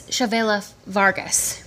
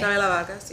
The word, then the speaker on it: Chavela Vargas: But in the film, I think it's Chavela Vargas, right? That's how you say her name? – Yeah. – Okay. – Chavela Vargas, yeah.